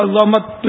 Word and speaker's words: عظمت: تو یہ صحیح تلفظ عظمت ہے، عظمت تو وہ ہڈیوں سے اس کا ہڈی عظمت، یہ اگر زا کو ہم ساکن پڑھیں گے نا عظمت تو یہ اس کے معنی ہڈی کے ہوتے